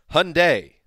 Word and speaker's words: Hyundai: Welcome to the Monday edition of the Around the NFL Podcast, driven, not sponsored, freaking driven by Hyundai, which rhymes with Sunday.